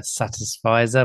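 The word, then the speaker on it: satisficer: I mean, the thing that comes to my mind is I'm definitely a satisfizer.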